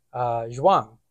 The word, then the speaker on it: Zhuang: Zhuang, I'll say Zhuang, because it's with an H. Z-H-U-A-N-G, Zhuang? – Sure.